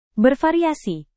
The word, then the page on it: bervariasi: Indonesian